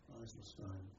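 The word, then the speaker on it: IJsselstein: Yes, that's how it's pronounced, I think, Eiselstein,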